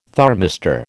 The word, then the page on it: thermistor: American English